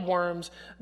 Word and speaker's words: Worms: Okay, the city called Worms, not the things crawling on the ground. It's actually pronounced Worms.